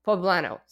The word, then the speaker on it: poblano: So, as I mentioned, the most celebrated food of this holiday is the mole pueblo. I don't know if I'm saying that right. Poblano.